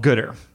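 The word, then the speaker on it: Goodr: So there's kind of this brand that I really like, and it's called Gooder. So not better, but Gooder. – Yeah, how do you spell that? – It's, I think it's G-O-O-D-R.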